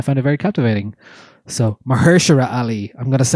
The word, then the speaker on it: Mahershala: It stars Mahershala. That's how you say it.